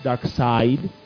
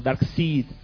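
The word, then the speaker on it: Darkseid: Y podría sonar como Dark Seed, pero no, es Dark Side.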